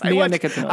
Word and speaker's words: neonicotinoids: Neonicotinoids. – Yes, nicotinoids. – Yes. – Neonicotinoids.